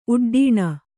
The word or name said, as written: ಉಡ್ಡೀಣ